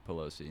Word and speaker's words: Pelosi: Pelosi, Nancy Pelosi.